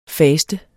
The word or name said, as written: fast